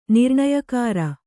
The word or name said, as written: ನಿರ್ಣಯಕಾರ